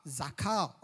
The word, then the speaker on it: zakar: The word remember in Greek is called Zakar. Z-A-K-A-R. Zakar or Zakar, the way you want to pronounce it.